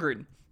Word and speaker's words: Gruden: So Grudem, how do you say it? – John Gruden, the football coach of the Las Vegas Raiders.